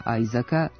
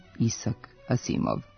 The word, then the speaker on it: Isak Asimov: Naprimjer, američkog pevača i glumca Chrisa Isaaca nećemo zvati Chris Isak, ali jedan od najvećih pisaca naučno-fantastičnih romana nije Isaac, nego Isak Asimov.